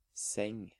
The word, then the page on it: seng: Norwegian